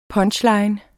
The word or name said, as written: PUNCHLINE